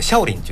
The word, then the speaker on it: 少林: あの中国の方でシャオリンって言うんですよ。